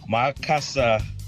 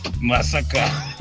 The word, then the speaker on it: Makassar: I think you meant Makassar in Western Cape, not Massacre.